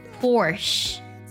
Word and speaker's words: Porsche: 它正确的英文发音读作 Porsche。